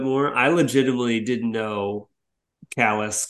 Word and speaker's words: Calais: And there's a there's a border town on the frontier there that's spelled C A L A I S. And I was just like, suspend everything, no book learning. How would you pronounce this? Callus.